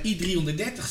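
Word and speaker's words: I-330: Maar volgens I330, ze leven natuurlijk, zeg ik dat goed? Ja, ze leven natuurlijk in een wereld die volledig gebaseerd is op wiskundige formules. – En eh, I330 zegt, er, er, is nooit een getal, het laatste getal of het hoogste getal.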